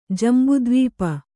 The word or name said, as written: ಜಂಬುದ್ವೀಪ